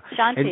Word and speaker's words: Shanti: Uh, you studied under Adi Shante? That's Shanti? right. Yeah. – Shanti, and, yes, you you said it perfectly.